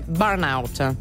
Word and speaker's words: Burnout: Tra l'altro lo pronunciamo male anche noi, l'ha pronunciato giusto il, la, l'ascoltatore prima, è Burnout.